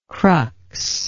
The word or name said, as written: crux